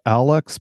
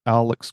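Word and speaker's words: ALUXX: I think it's pronounced Alex, but it's A L U X X. And so they call it their Alex grade aluminum frame.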